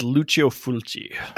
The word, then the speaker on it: Lucio Fulci: How do you say this director's name? – I'd go with Lucio Fulci. – Lucio Falchi.